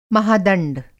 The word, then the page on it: महादंड: Hindi